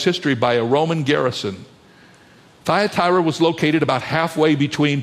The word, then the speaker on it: Thyatira: Say the word out loud with me, Thyatira. You won't believe how that word is pronounced by some folks, but that's the way it's pronounced, Thyatira.